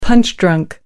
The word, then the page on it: punch-drunk: American English